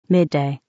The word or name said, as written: midday